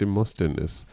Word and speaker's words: Demosthenes: Tatsächlich soll Nebis in Idem bis auf den griechischen Redner Demosthenes zurückgehen. Und der hat immerhin etwa 300 Jahre vor Christus gelebt. – Und ich also würde fast klugscheißen, er heißt Demosthenes. – Kannst du auch so aussprechen.